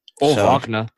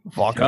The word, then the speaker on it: Wagner: I'll say real quick about the not learning to properly say Mo Wagner, if I'm saying that right, or Wagner. He has a brother coming into the league. – Wagner. – Yeah, Wagner. German, yeah. Wagner.